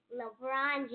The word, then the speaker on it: LeBron: LeBron James.